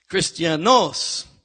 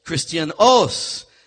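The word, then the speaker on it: Christianos (Χριστιανός): Christianos. Emphasis on the end os. Christianos.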